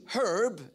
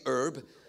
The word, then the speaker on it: herb: I know here we say herb. Down under we pronounce the H, we say herb, just like you wear a hat and you have hair.